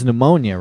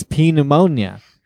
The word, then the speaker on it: pneumonia: When I read it as a child, the kid has pneumonia, right? And I didn't know that pneumonia had a silent P. And so I was reading it. I was reading it to myself as P pneumonia.